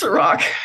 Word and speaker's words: Cirak: So Chris Cirak is here.